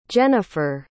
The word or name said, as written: jenniffer